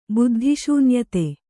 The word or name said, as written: ಬುದ್ಧಿಶೂನ್ಯತೆ